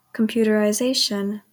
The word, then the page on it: computerization: /kəmˌpjuːtəɹaɪˈzeɪʃən/